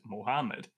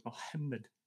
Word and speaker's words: Mohammed: we pronounce it Mohammed, but the actual sound is made further back in the throat. So it's Mohammed.